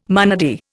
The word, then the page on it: monody: Indian